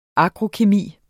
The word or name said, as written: agrokemi